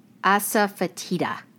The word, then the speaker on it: asafoetida: What is asafoetida? Did I say it right? – Yes, you did.